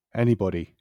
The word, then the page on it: anybody: British English